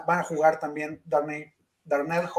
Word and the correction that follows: Darnay: Holmes, ¿cómo se llama? Darnay, Holmes.